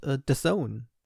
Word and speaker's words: DAZN: Dazu gehört The Zone. Ja, DAZN. Ich habe das früher immer falsch ausgesprochen. Für mich ist es einfach nicht begreiflich, woher das O und das E kommen.